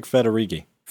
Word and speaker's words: Federighi: Well, is it Federighi? I don't know if I said that right. – Oh, yeah. Craig Federighi.